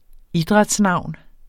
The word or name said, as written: idrætsnavn